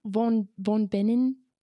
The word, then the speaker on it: von Bingen: Von Bingen. – I think my professor said Hildegard von Bingen.